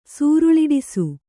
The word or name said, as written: ಸೂರುಳಿಡಿಸು